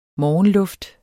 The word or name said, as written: morgenluft